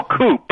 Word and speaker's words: coup: Yeah, C-O-U-P. – Oh, coup. – No, coup. You don't. The P is silent.